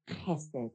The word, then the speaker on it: chesed: Now today, I talked to you a little bit about this word chesed, is how you say it. You've got to get the going. Chesed. Sometimes it's spelled with a K before the H to try and give you that idea. Or I get too close. Chesed.